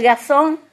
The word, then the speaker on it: garçon: How do you say it now in French? Yeah. Garçon. You got it.